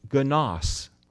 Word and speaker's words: genos: But there's another Greek word very close to this which is pronounced genos.